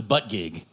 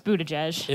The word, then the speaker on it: Buttigieg: Is that how it's said? Because I thought it was Buttigieg. – No, it's Buttigieg.